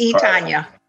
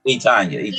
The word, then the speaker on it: Etonia: Is that Etonya or Tanya Carter? Which one is it, Miss Etonya? Etonya.